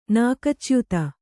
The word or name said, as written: ನಾಕಚ್ಯುತ